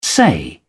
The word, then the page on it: say: British English